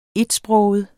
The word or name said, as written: etsproget